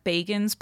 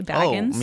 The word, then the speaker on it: Bagans: And just to clarify, his name is pronounced Zach Bagans, but I'm like still in denial about that. And I still say Baggins.